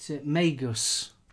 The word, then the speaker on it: Magus: And then I'm also on the, we now pronounce it, I forgot how you pronounce it, what was it? Magus. – Yes. – M-A-G-U-S from Yuwu.